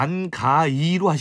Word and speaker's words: 안개: 그렇죠, 안가인데 이게 고어식 발음이란 말이에요. – 예. – 그러니까 옛날 표기법을 보면 안개를 쓸 때 가짜를 쓸 때 아래아짜 가짜를 쓰고 따니를 한단 말이에요. 옆에다가 안가이죠.